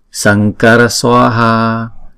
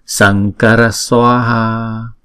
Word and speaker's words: Sankara: Karunika, sankara swaha. Chữ này đọc là chữ ngờ ha, chữ N có dấu chấm ở trên này là ngờ. Sankara Swaha.